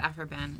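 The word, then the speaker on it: Aphra Behn: Am I saying it right? Afra Ben? – Afra Ben is how we pronounce it. – And it's an interesting name. A-P-H-R-A, last name B-E-H-N.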